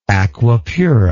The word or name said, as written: aqua pura